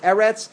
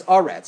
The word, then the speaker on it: Eretz: Sometimes it's pronounced Eretz, sometimes it's Aretz. Sometimes it's Shamayim with a patach, sometimes it's Shamayim with a kamatz.